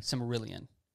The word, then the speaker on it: Silmarillion: That's how you pronounce it. – Silmarillion. – Yeah, Silmarillion.